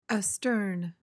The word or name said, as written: astern